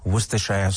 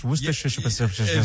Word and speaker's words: Worcestershire: I can see my grandfather taking out the Worcestershire sauce. Did I say it right? Worcestershire sauce. – The first time was better than the second, yeah.